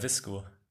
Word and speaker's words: VSCO: Ja, Visco.